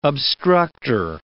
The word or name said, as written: obstructor